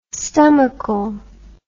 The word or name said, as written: stomachal